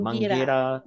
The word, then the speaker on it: Mangueira: Is it Mangueira? – Mangira. – Mangueira, the Yeah.